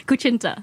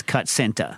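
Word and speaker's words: kutsinta: Kuchinta. – That's how you pronounce that? – Yeah. The S, T-S is...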